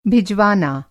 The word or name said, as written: भिजवाना